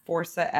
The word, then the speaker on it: Forca: My Instagram is at Forsa, F-O-R-C-A dot nature, N-A-T-U-R-E. Part of that is so it helps people learn how to pronounce it.